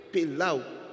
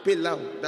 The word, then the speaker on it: Pelau: The Pelu. – Pelau. Like chunky thing. Say like a Chinese. Rhymes with now. – Lau. – Pelau.